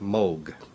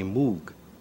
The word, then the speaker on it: Moog: In Germany, it's a fairly common name, and there it's pronounced Moog. In Great Britain, English-speaking countries, people see the double O and they say Moog. Now, I just happen to like the sound of Moog better than Moog. Part of my family pronounces it one way and part pronounces it the other way. So my wife at the time and I decided to go for the Moog pronunciation.